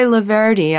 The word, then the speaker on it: Loverde: I'm your host, and I've been talking with Joy Loverde. I mispronounced that the first section, but now I've got it right.